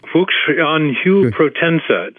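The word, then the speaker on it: Fuxianhuia protensa: I don't know if I'm pronouncing it Fuxianhuya. – Fuxianhuya protensa.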